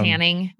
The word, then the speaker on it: Haining: Close. It's how it's spelled. – It's Hanning. It's just Hanning.